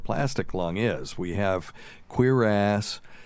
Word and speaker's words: cuirass: Cuirass. – I'm thinking cuirass. – Like caress.